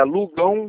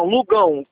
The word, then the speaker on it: Lugão: É, Lugão Ribeiro. – O Lugão se escreve Luao, é isso? – Luau. – Aluão, não é isso? – Não, Lugão. Gaotil.